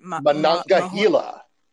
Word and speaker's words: Monongahela: Monongahela. – Mahanganila rye styles.